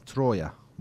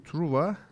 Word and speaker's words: Troya: Çünkü Truva kelimenin e, Fransızca telaffuzu. Kentin asıl ismi Troya. E, üstelik Türkçe telaffuza da çok uygun. Dolayısıyla Fransızca telaffuzu tercih etmek gibi bir e, gereklilik yok açıkçası. O bakımdan Truva yerine Troya demek e, gerekir.